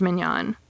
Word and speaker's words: mignon: This time, it kept its original spelling, M-I-G-N-O-N, and it's pronounced somewhat differently, as mignon.